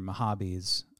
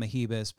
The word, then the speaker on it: Mahabis: And I've been mispronouncing Mahabis as Mahibis for years, thinking it was a funny bit.